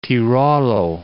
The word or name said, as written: Tirolo